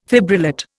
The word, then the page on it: fibrillate: Indian